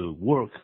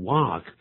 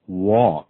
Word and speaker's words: walk: 在这里啊，这个 walk 大家知道，在发音的时候可不要念成 work 啊，或者是 work 搞不清楚，那个在美式英语里头它念成 walk。我们请呢 再为我们念一下 walk W-A-L-K walk work W-O-R-K work